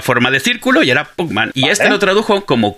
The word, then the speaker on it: Puckman: El nombre original de Pac-Man es Puckman, P-U-C-K. pronuncia parecido, pac pero no era Pac-Man todavía porque todavía no era para mercados internacionales, era para Japón.